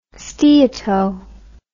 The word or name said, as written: steato